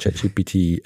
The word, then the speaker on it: ChatGPT: Ja, also wir verwenden momentan oder bieten da von OpenAI ChatGPT, habe ich es richtig gesagt? ChatGPT 4.0. – Jawohl.